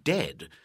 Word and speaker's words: dead: Beware of herd, a dreadful word that looks like beard and sounds like bird and dead. It's said like bed, not bead. For goodness sake, don't call it deed.